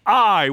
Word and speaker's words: Ai: To the east stands Ai, which is actually how you're supposed to pronounce it. Most people say Ai.